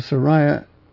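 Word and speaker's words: Seraiah: And after these things, in the reign of Artaxerxes, the king of Persia, this is Ezra chapter 7, Ezra the son of Sariah, the accent on the I. Ezra, the son of Sariah,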